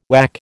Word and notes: WAC: American English

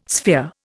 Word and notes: sphere: Indian

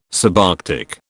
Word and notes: subarctic: British English

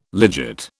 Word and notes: legit: British English

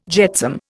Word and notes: jetsam: Indian

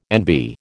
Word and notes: NB: American English

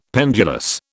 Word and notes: pendulous: British English

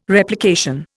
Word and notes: Replication: Indian

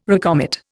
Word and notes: recommit: Indian

